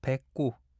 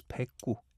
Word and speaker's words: 백구: It's called 백구. Well, I don't know why, but I think I see why at the same time, because 109, it could also be pronounced as 백구 in Korean.